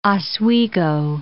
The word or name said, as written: Oswego